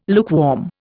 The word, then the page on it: lukewarm: Indian